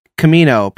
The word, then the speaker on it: Kamino: Kamino, pronounced Kamino, called Big Stormy by some clone troopers, also known as the Planet of Storms.